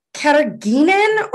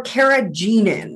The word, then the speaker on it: carrageenan: It's called carrageenan or carrageenan. – Let me try. Let me see. I feel like the two E's make it a soft G. Carrageenan.